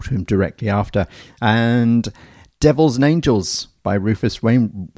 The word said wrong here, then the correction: Rufus Wainwright: I can't say his name properly. Rufus Wainwright.